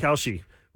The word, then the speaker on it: Kalshi: What's that website? Kashi? Or what is it? Kalshi? How do you say Kalshi. – It? Kalshi. Where you can make bets on anything.